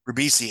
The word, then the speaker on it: Ribisi: This is one of my favorite scenes in the movie, where as much of an asshole as Giovanni Ribisi, I can never pronounce his name, I apologize. – Ribisi is, he actually is talked into trying a last minute attempt to prevent the genocide.